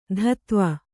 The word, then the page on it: ಧತ್ವ: dhatva